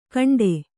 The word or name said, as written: ಘಂಟೆ